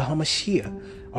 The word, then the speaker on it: HaMashiach: Your life is hid with the HaMashiach. Okay, the anointed one. That's how you say it in Hebrew.